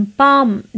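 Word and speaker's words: palm: पाम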